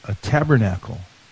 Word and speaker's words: tabernacle: This is called a tabernacle. Tabernacle. Tabernacle. Four syllables.